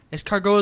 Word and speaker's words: escargot: Yeah, escargot. That's how you say it.